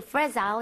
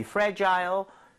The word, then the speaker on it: fragile: Yeah, there's something fragile inside. – There you go. 다시 한번 이 fragile Fragile, 둘 다 발음 괜찮습니다. Fragile은 조금 더 미국에 가깝고 Fragile, 영국에 가깝지만 요즘에는 다 섞어서 쓰는 편이에요.